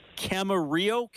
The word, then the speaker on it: Camarillo: Tell me about growing up in, and forgive me if I'm pronouncing it wrong, what was life like as a kid in Camarillo, California? Is that how you say it? – That is perfect. That is great pronunciation of that.